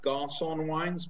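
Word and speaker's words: Garçon Wines: It's pronounced Garcon Wines, but it's spelled G-A-R-C-O-N, wines in the plural, .com.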